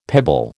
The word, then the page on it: pebble: American English